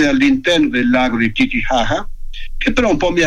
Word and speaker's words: Titicaca: In bus siamo andati a Puno, che è la città che si affaccia sul lago di, si scrive Titicaca, ma si pronuncia Titicaca.